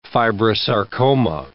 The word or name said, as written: fibrosarcoma